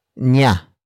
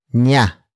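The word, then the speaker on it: niya: And finally, we have nya. Nya.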